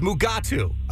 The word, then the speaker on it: Mugatu: You're not even saying that right. – It's Mugatu.